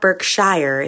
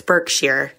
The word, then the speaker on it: Berkshire: It's not Berkshire, it's Berkshire.